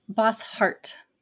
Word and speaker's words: Bosshart: Bosshart. – Bosshart. – Yep. – Just like it's written.